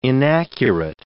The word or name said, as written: inaccurate